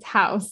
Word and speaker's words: Haus: like isn't aware, Haus is spelled Haus. So it's not yes. Haus, it's Haus.